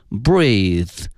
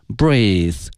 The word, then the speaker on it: breathe: 同时啊，呼吸这个词因为呢它的词性是名词，因此它的发音是 breath，而它的动词呢是 breathe， breathe。